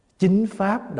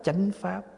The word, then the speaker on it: Chính pháp: Chính pháp đọc là chánh pháp, vì người Bắc đọc chính, mình đọc chánh.